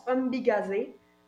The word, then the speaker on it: Ombigizi: Le projet s'appelle, se prononce Ombigazé. Ça s'écrit Ombigizi, Ombigizi. On va aussi publier ça sur les réseaux sociaux.